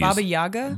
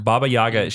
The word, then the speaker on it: Baba Yaga: Baba Yaga? – Baba Yaga.